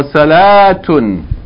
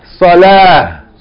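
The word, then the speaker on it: Salah (صلاة): Not if you stop on it. We don't say salat. You say salah.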